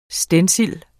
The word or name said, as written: stencil